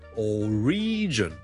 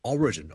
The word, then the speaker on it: origin: Number two 咧，如果讀成 origin 咧，就會怪怪地啦，因為個重音其實 origin O-R-I-G-I-N 個來源咧，我哋個重音係第一個音節嘅，所以 single law origin。